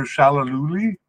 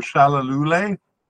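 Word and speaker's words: Shalulile: We have a definite candidate in Peter Shalaluli, or Shalalule, I think it's pronounced.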